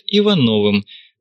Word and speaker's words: Иванов: Но сейчас меня чаще называют Ивановым, ударение на первом слоге по-офицерски.